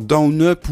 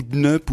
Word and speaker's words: DNUP: Alors, Down Up ou Dnup ou Dnup, enfin, il va porter plein de noms ce jeu.